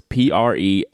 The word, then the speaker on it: Prefontaine: And I basically said, oh my gosh, how do I say this? So it's Prefonte. It's a terrible name. It's the Platinum Prefonte.